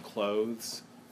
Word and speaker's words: clothes: So the TH in clothes rhymes with goes and knows, because TH really very easily slips into S in the history of languages.